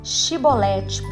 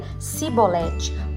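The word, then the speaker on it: Chibolete: Então lhe diziam: Dize, pois, Shibolete. Porém, ele dizia: Sibolete, porque eu não podia pronunciar assim bem.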